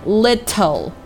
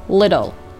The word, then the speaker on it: little: Every 第二个重点单词 little 啊，如果字母 t 位于字母 l 前面的话，咱们最好把它进行浊化，不要读成 little，而是要读成 little。